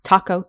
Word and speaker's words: taiko: In 1000 to 500 BC, so let me pronounce this correctly, taiko drums from Japan.